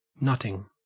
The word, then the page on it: nutting: Australian English